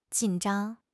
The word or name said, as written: jin3 zhang1